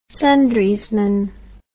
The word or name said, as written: sundriesman